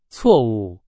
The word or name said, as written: 错误